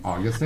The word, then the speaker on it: August: Did I say her name right? – Yeah. August. – Oh, okay. That's right. People call her Augusta, and it was.